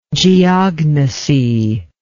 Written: geognosy

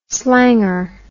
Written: slanger

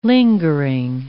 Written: lingering

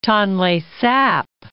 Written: Tonle Sap

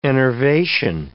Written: enervation

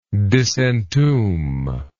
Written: disentomb

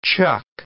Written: chuck

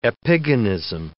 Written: epigonism

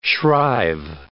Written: shrive

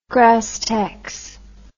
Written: grasstex

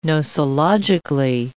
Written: nosologically

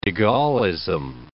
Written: de Gaullism

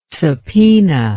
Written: subpoena

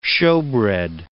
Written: shewbread

